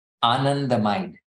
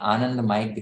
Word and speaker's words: anandamide: I'm saying anandamide because I'm Indian and I know the exact pronunciation of the Sanskrit word anand. But most people in the US would call it anandamide. So anandamide is what you'd say, but anandamide is what it really is.